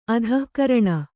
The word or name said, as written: ಆದಿಕಾರಣ